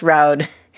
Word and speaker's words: Roud: for teaching us how to pronounce Roud. It rhymes with loud. His name is not Rude, we've learned.